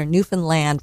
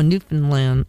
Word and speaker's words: Newfoundland: I had to learn how to pronounce it. I was corrected when I got there. Newfoundland rhymes with understand. – Oh, Newfoundland.